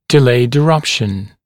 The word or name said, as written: delayed eruption